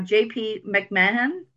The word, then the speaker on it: JP McMahon: Today's guest, I have JP McMahon.